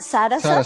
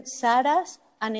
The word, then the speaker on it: Saras: It's pronounced Sara Sa. – Sara Sa. – Yes. But it's written without the last A. So it's written Saras and it's pronounced Sara Sa.